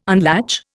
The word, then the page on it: unlatch: Indian